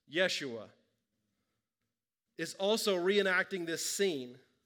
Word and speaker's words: Yeshua: In the Old Testament, Joshua, which is pronounced in Hebrew, Yeshua, leads Israel through the waters of the Jordan and into the Promised Land.